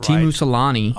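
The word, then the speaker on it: Teemu Selänne: I mean, they take Timu Selanne, I think I said that right. – Timu Selanne.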